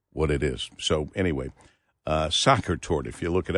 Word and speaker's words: Sacher: We are pronouncing it, I was pronouncing it incorrectly, Sacher Tort Day, because I S-A-C-H-E-R. She said it's actually pronounced Soccer Tort, which is should be easier to remember for me, but I leave it up to the women.